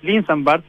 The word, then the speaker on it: Linsenbarth: Sí, bueno, es Linsenbarth, pero en verdad se pronuncia con D o con TH final, así que no hay problema.